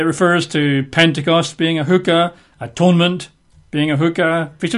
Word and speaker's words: chuqqah: Well, statutes in Hebrew is a word which I believe is pronounced as a hookah, not a lady of ill repute that you find in nightclubs. Yeah, hookah is spelled in English letters C-H-U-Q-Q-A-H. That's how it's normally written. C-H-U-Q-Q-A-H, hookah.